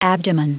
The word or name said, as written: abdomen